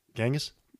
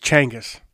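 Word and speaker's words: Chinggis: Genghis? – Exactly. Here's my geek thing. – Yeah, give it to me. – We pronounce it wrong. It's Changus.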